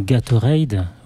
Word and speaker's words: Gatorade: Avant de retrouver Louis pour sa chronique Gatorade.